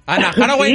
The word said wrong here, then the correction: Anne Hathaway: Era Anne Hathaway. – Que se le instruya, dice. – ¿Cómo se escribe? ¿Cómo se pronuncia? – Anne Hathaway. – Hi, how? – Anne Hathaway. – Como hack dog. Anne Hathaway.